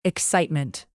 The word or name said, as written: excitement